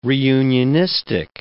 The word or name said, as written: reunionistic